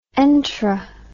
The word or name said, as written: entera